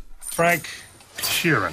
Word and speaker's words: Sheeran: Frank Sheeran.